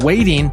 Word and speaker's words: Weighting: And she's got a new project called Waiting. And I'm going to spell it for you since it's radio. W-E-I-G-H-T-I-N-G.